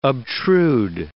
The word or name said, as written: obtrude